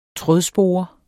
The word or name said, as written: trådspore